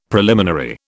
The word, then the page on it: preliminary: British English